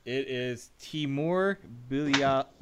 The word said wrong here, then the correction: Bilyalov: Oh, Timur Bilyalov.